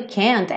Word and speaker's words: can't: Esse T do can't é muito comum ele não aparecer.